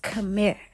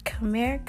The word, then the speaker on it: Khmer: That means hello in Cambodian, in the language of Khmer. Khmer, Khmer, Khmer. K-H-M-E-R.